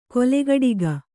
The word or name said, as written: ಕೊಲೆಗಡಿಗ